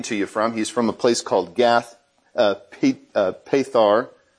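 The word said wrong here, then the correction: Gath-hepher: I'm not saying that right. Gath Hafar, which is just right next to Nazareth.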